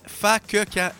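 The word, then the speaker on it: Fa que quand: Ça se prononce, c'est pas ce que tu penses. Fakeka. F-A. Espace. Q-U-E. Espace. Q-U-A-N-D. Fakeka.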